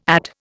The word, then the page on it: add: Indian